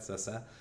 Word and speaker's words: Cessé: So my name is Cessé, and Cessé is just the initials CC in Portuguese, and it's pronounced like that, Cessé.